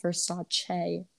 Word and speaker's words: Versace: but it's like it's pronounced Versace. Like, get it right.